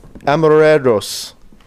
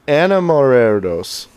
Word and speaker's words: Enamorados: They know the holiday as El Día de los Enamorados. I'm not saying that right. Enamorados. Day of Lovers.